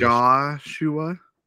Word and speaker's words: Joshua: So the technical pronunciation is Joshua. It's like two shush noises after you say the word Joshua. So then Joshua is kind of what people usually say.